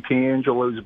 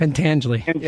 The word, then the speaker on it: Pentangeli: Just, real fast, on the Eagles, I feel Jeffrey Lurie pulled a Michael Corleone and bringing in Frankie Piangelo's brother. – Wow, that's a great analogy. – That is a great analogy, dude. That's except, awesome. – Except Frankie, how do you say the name again? – Pentangeli.